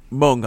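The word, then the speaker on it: Mwng: Did I say that right? – Mung, I thought it was, yeah. I thought it was, but I didn't say it. – It means mane, like a lion's mane.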